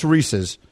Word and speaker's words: Reese's: Well, for starters, I'd like to say the bad form is him just continually mispronouncing this candy. – Correct. It's Reese's.